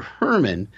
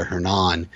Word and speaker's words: Hernan: But then later on, a few minutes later, the inspector calls him Hernan, and everyone really uh, stresses that final syllable there, Hernan.